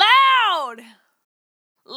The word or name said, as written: Loud